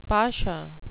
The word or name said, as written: pasha